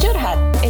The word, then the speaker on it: curhat: It's pronounced curhat. – It's an Indonesian term. It literally means rambling about your feelings.